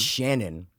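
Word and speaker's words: Channon: Dude, murders of Channing Christian and Christian Newsom, or whatever the name is. Christopher Newsom. – It's Shannon. – Oh, it's spelled weird. – Yeah. – It smells like Channing.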